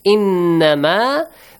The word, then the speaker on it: innama: Innama, the Noon Mushaddad, which is pronounced with a nasal sound.